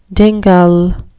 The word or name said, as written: dingle